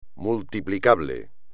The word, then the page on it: multiplicable: Spanish